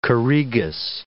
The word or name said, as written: choregus